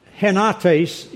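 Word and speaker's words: henates (ἑνότης): So henates is the way you pronounce that Greek word, a state of oneness or being in harmony, in accord.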